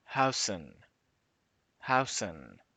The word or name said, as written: huijsen